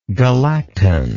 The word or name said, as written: galactan